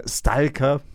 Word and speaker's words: Stalker: Stalker. Spreche ich es doch jetzt mal lieber aus, weil in der berühmten Deversynchronisation wird das englische Wort in Stalker ausgesprochen. Da bleibe ich auch gern dabei.